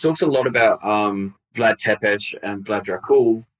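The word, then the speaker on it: Țepeș: Vlad Tepes, or Tepes is actually the more correct way to say it, the son of Vlad Dracul, is born.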